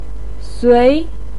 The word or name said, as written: sui2